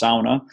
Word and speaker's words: sauna: And it wasn't until people started to invite me to the sauna, we say sauna, but of course it's a Finnish word. It's the only Finnish word in the English dictionary, so it's pronounced sauna.